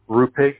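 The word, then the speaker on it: Rupeiks: Rupix. – Okay. And forgive me, spell that one more time. – R-U-P.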